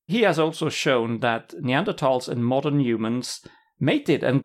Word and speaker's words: Neanderthals: And yes, it's supposed to be pronounced Neanderthals with a hard T. Isn't that right, Annika? – Yes, because it's referring to a valley. – It's the valley. Mm-hmm. So don't forget about the H. That's just for English people or English-speaking people. It's Neanderthals.